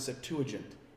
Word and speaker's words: Septuagint: So the correct pronunciation is something along the lines of Septuagint.